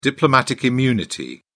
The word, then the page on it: diplomatic immunity: British English